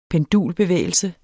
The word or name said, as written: pendulbevægelse